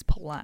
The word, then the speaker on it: Palou: In IndyCar, he's Hello. Become Palou, but I think that's just Americans saying it wrong. I think it is Palou. – Right, okay. – Palou sounds correct.